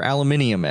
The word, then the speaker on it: aluminium: Yeah, eat some fucking aluminum. Or aluminium, as the British say it.